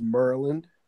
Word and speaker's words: Maryland: It's pronounced Merland. – Merland. – M-U-R. – Merland. – L-Y-N. With an A between.